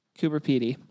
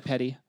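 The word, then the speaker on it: Coober Pedy: So we are heading to, how do you say that? – Cooper Petey. Yeah. Just exactly how I might have said Petty.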